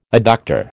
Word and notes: adductor: American English

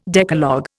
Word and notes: Decalogue: Indian

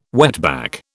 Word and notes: wetback: British English

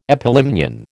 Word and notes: epilimnion: American English

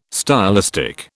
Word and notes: stylistic: British English

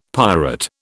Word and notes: pirate: British English

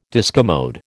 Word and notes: discommode: American English

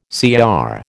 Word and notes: CR: American English

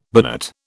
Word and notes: binate: British English

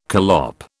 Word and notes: collop: British English

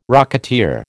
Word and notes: rocketeer: American English

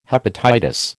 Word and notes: hepatitis: American English

